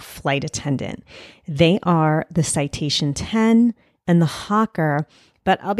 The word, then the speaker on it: Citation X: The citation 10 is written as citation X, but it's pronounced 10 like the Roman numeral.